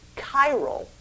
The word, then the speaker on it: chiral: An object is said to be geometrically chiral, that's how you pronounce that, if it cannot be rigidly superimposed, that is, rotating it, moving around, onto its mirror image.